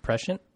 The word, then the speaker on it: prescient: And if it does, I will give you the same shout out that we did to the listener and call you presentient or presentient. How do you say that word? – Prescient.